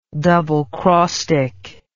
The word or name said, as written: doublecrostic